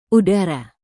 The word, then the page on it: udara: indonesian